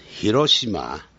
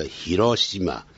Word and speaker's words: Hiroshima: 第一题 I stayed in Hiroshima，日本话的发音叫 Hiroshima，如果老外发音 Hiroshima， for another year blank more about Japanese history and culture。